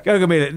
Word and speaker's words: Gaugamela: But first, he's got to get through Gaugamela. – That's right, yeah. First of all... – Did I pronounce that right? – Gaugamela. – Gaugamela.